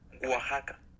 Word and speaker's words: Oaxaca: Is it Oaxaca? – Oaxaca.